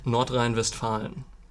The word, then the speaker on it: Nordrhein-Westfalen: How do you say that in German? – Nordrhein-Westfalen, or Nordrhein-Westfälische.